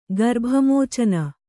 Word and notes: ಗರ್ಭಮೋಚನ: kannada

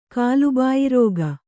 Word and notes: ಕಾಲುಬಾಯಿರೋಗ: kālu bāyi rōga

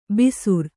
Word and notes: ಬಿಸುರ್: Kannada

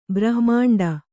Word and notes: ಬ್ರಹ್ಮಾಂಡ: brahma'ṇḍa

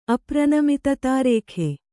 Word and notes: ಅಪ್ರನಮಿತತಾರೇಖೆ: apranamitata rēkhe

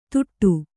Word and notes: ತುಟ್ಟು: tuṭṭu

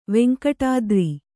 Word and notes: ವೆಂಕಟಾದ್ರಿ: kannada

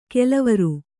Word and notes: ಕೆಲವಾರು: kelavāru

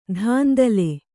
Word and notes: ದಾಂಧಲೆ: dāndhale

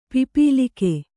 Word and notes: ಪಿಪೀಲಕ: Kannada

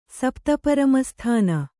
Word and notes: ಸಪ್ತಪರಮಸ್ಥಾನ: Kannada

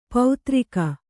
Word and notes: ಪೌತ್ರಿಕ: pautrika

